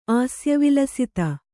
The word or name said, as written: ಆಸ್ಯವಿಲಸಿತ